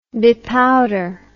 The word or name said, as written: bepowder